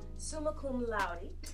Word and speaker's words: summa cum laude: Summa cum laude.